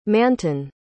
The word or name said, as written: Manton